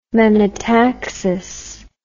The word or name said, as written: menotaxis